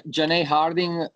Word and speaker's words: Janay Harding: I hope he's saying the name properly. Janae Harding, isn't it? Like for her win in Bellator. – Yeah, Janae Harding.